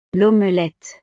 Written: l'omelette

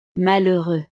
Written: malheureux

